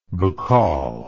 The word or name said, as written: Bacall